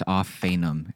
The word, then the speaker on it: Ophanim: It's Ophanim is how it's pronounced. – Ophanim.